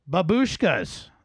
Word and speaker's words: Babushka: And I mispronounced that last week. I got a lot of reader mail about that, and people are very offended. Babushka.